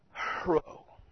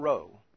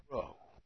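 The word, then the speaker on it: rho: That one is called Hro. Not Ro. Hro. It is pronounced as if it were H-R-O-W. But fortunately, you would have to worry about that in a word only if it comes at the beginning of the word.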